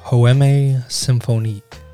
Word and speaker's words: Poème: Poème Symphonique.